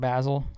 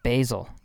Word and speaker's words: Basel: Like Art Basel? I don't even know what that is. What is Art Basel? It's pronounced Basil, you know?